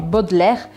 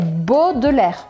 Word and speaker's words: Baudelaire: Esse nome, Baudelaire, parece que tem um monte de letra, mas você não tem que pronunciar a metade delas. Então fica assim o, o som mesmo. Baudelaire. Eu já ouvi muita gente falando Baudelaire. É Baudelaire, Baudelaire, Baudelaire.